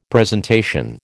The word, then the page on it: presentation: American English